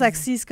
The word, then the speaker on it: accise: On a dit assise pendant des années. Puis là, tout le monde s'est dit depuis une semaine, il faut qu'on prononce accise correct.